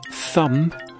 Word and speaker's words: thumb: And the answer to number 11, thumb, the B is silent.